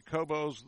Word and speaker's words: Kobos: So after taking a second look at the pronunciation guide, probably Kobos the way to go with the emphasis on the co portion, the 1-0 from Kobos.